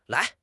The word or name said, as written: lai2